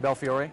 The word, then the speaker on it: Belfiore: So on Monday, when we had Scott Guthrie, Joe Belfiore, and I can never say his name right. – Belfiore. – I'll get that right one day, I promise.